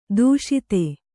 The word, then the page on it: ಧಾಷತ್ತು: dhāṣattu